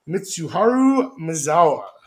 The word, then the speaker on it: Mitsuharu Misawa: Yes, the promotion was founded by a group of All Japan Pro Wrestling defectors led by Mitsuharu Misawa. – Dude, nice. That is exactly how you pronounce his name.